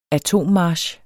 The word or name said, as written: atommarch